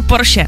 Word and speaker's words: Porsche: A pak tady ještě Porsche, jo. To my Češi říkáme skoro ze všech jako národů jediný správně. Všichni říkají Porsche špatně, ale je to opravdu Porsche.